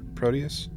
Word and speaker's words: Proteus: What we what we fi- find out is that it's this thing called Project Proteus, right? I'm pronouncing that right? Proteus? – Yes.